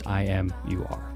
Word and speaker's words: IMUR: Yes, it's pronounced IMUR. It's also spelled IMUR.